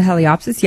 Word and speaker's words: Heliopsis: Yeah, well, actually, the hollyhocks are fairly close to where the, I'm not sure how to say it, Heliopsis? – The Heliopsis, yeah.